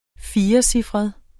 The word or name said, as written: firecifret